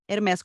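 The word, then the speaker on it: Hermès: mas Hermes com S, então não me corrijam, não tentem afrancesar.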